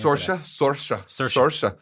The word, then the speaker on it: Saoirse: Saoirse Ronan. I actually had to remind myself how to pronounce her name. – Saoirse.